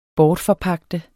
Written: bortforpagte

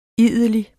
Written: idelig

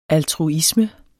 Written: altruisme

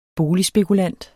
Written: boligspekulant

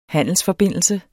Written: handelsforbindelse

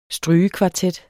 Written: strygekvartet

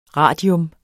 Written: radium

